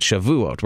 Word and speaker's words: Shavuot: I believe that, I'm not sure if I pronounced it correctly, Shavuot, that's it, Shavuot.